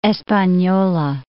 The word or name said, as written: Espanola